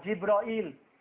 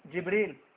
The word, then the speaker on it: Jibril: Anh xin liệt kê đây, thứ nhất là tôi biết rồi, đại thiên thần Jibril, và cũng có một số người đọc là Jibroil, thì đều như nhau cả, đều là gì? Jibril.